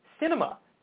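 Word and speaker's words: Sinema: The Arizona senator's name is pronounced Cinema,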